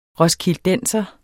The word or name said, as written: roskildenser